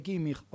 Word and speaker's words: Kimmich: Es más, pronunciaba, viste que nosotros, qué sé yo, por ejemplo, no sé qué jugador te puedo decir, Kimmich, y él, nosotros decimos Kimmich, porque se escribe así, y él dice Kimmich, o sea, habla alemán el tipo.